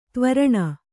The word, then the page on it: ದೇವ ಋಣ: Kannada